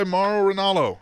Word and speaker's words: Mauro Ranallo: Great first night of announcing by Mauro Ranallo.